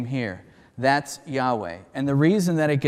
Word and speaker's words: Yahweh: And we usually pronounce that Yahweh, and it means I am.